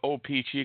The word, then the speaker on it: O-Pee-Chee: I basically call it O-Pee-Chee because it's kind of got like little dash marks after it. It sounds like it should be said by each syllable.